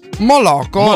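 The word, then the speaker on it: Moloko: E rimaniamo alla fine degli anni 90 con Moloco o Moloco, adesso non so dove va l'accento. Moloco, allora avevo detto bene, grazie per il suggerimento, che ho il suggeritore qui personale stasera, e che suggeritore.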